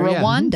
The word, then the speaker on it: Rwanda: That's how you say it, right? – I've been there. – Rwanda. – Yeah.